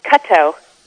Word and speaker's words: Korto: Korto Momolu. – Kato. – What's that? – It's pronounced Kato. – Kato, okay. I'll have to take your word for it.